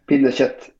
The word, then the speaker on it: Pinnekjøtt: Sorry, I'm gonna really struggle to pronounce it. Pinnekjøtt? – Pinnekjøtt, yes.